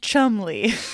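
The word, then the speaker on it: Cholmondeley: It's pronounced Chumley. – Really? – That is wild. Yeah, it looks like a 5-syllable name, but it's a 2-syllable name.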